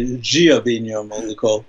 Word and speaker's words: Dia Binho Maluco: If you're Brazilian, say Dia Binho Maluco. They pronounce the D kind of like a G, but that's very good. – Dia Binho Maluco.